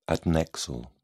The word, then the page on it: adnexal: British English